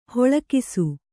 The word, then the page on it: ಹೊಳಕಿಸು: hoḷakisu